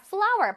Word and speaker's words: flour: F-L-O-U-R. 바로 flower입니다. 자, flower. 발음은 꽃 flower랑 같은데 스펠링이 다르죠? 그치만 발음은 같아요.